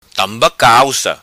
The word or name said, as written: Tamba Calca